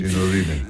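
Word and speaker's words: Sinudyne: el Real Madrid de baloncesto se enfrenta al Sinudí de Bolonia, creo que se dice así. – Sinudí.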